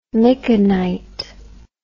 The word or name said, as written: micanite